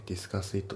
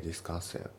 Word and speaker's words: Discuss it: で発音ですけど Discuss it のところがリンキングで Discuss it、ね、 Discuss it じゃなくて Discuss it、ね、あと it の t が抜けますね。